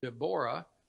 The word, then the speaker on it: Deborah: Barak's story is found in Judges 4 and 5, and he was teamed with Deborah, and we usually would say Deborah, but it's actually pronounced Deborah.